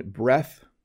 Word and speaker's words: breath: So once again, breath, no E on the end, and it's just pronounced breath.